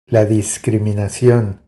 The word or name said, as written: La discriminacion